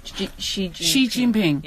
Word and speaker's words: Xi Jinping: So let me ask you, I just did a breakdown of Xi Jinping. Am I saying that correctly? – Yeah. – Xi Jinping? – Yeah. – Xi Jinping.